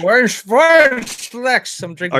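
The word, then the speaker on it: Vorinclex: How did you pronounce that? Vornschlex? – Vornschlex.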